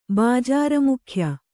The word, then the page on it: ಬಾಜಾರಮುಖ್ಯ: Kannada